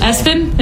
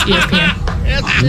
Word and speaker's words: ESPN: I said Espen, and he's like, no, it's literally pronounced ESPN.